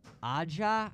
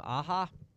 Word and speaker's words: Aja: And it's a sort of visual aesthetic to the 2012 to 2015 run of Hawkeye by Matt Fraction and David Aja, I think is how you say his last name, Aja, the writer and the artist.